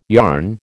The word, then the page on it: yarn: American English